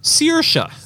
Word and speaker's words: Saoirse: To the best of my knowledge, it's pronounced Saoirse. – Saoirse. – Like you're searing a steak.